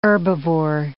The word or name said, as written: herbivore